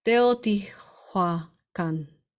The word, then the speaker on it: Teotihuacan: In Teo de Tijuana. I think I said that right. No, I don't think I said that right. Teotihuacan.